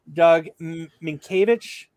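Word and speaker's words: Doug Mientkiewicz: Doug Minkiewicz. – Yep. – That's how you say his name. – That's how you say it's spelt different than you say it.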